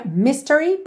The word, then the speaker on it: mystery: Normalmente seria mystery, ok? Se você estivesse apenas dizendo essa palavra, a sílaba tônica é a primeira.